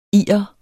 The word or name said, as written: irer